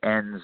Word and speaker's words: Enns: Then when I started working on it, started researching it and discovering, for instance, how important he was to the town of Enns, Austria, it's spelled E-N-N-S in case it's hard to understand.